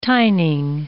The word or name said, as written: tining